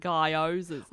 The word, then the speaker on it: gyoza: It's like Guy Pearce, like gyozas.